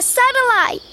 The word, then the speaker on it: satellite: A satellite. – Niños, niñas, presten atención a la pronunciación satélite y satellite. Se pronuncia diferente. Escuchen de nuevo con atención. Satélite y satellite. Repitan conmigo. Satellite.